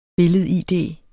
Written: billed-id

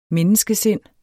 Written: menneskesind

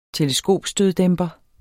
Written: teleskopstøddæmper

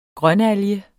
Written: grønalge

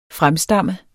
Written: FREMSTAMMENDE

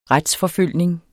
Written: RETSFORFULGTE

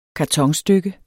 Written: kartonstykke

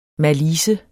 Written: malice

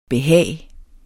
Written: behag

